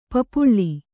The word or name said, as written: ಪಪ್ಪಳಿ